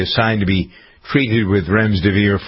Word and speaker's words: remdesivir: It's not remdesivir. It's remdesivir. Like, spell it out phonetically, Mr. Former English teacher.